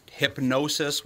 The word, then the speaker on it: Hipgnosis: So what it is the hypnosis, which is spelled HIPGNOSIS, Songs Fund, owns Neil Young's music.